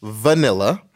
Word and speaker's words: vanilla: H-U-E. – 이게 그 또 뭐야. Vanilla 같은 경우는 발음이 A-B, B가 아니죠. – V 발음이에요. Vanilla, no. It's V, Vanilla.